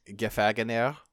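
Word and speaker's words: Gefangener: So there is the Fagener gym. I cannot pronounce that. That's it's the captured gym. – Gefagener. – It's German for prisoner or captive.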